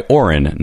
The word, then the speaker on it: Oren: Woody Harrelson got nominated for a Best Supporting Actor Oscar for The Messenger, and it was directed by Oren, not Owen Moverman.